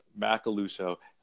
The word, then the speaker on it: Macaluso: so my last name Macaluso very phonetic. It is pronounced exactly as it's spelled, and nobody ever gets it right.